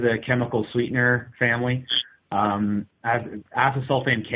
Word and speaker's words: acesulfame: It's asasulamine, As, acesulfame.